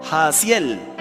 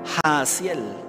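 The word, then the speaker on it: Jahaziel: ¿Cómo se lee eso? Hasiel Porque la H es muda, no se pronuncia, porque yo la pronuncié. Hasiel Escuchen habitantes de Judá y de Jerusalén.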